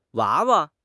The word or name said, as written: 娃娃